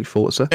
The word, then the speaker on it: Forza: Forza? – It is Forza, like pizza.